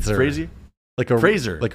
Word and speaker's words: Fraser: And then also, I feel like recently within the past, past maybe 5-6 years, Brendan Fraser, am I saying that right? – Fraser. – Like a razor.